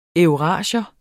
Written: eurasier

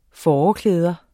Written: fåreklæder

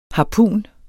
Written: harpun

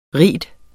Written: rige

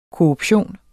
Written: korruption

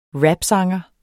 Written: rapsanger

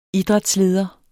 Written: idrætsleder

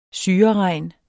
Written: syreregn

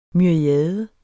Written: myriade

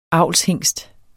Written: avlshingst

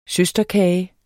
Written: søsterkage

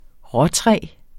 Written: råtræ

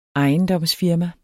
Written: ejendomsfirma

